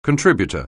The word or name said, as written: contributor